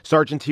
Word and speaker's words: sergeanty: How do I know it's pronounced sergeanty? I researched it and did not stop on the first YouTube video that purported to tell me how to properly pronounce sergeanty.